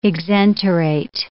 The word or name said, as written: exenterate